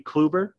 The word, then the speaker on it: Kluber: Kubler? How do you pronounce it? – I'll give it to you, Corey Kluber.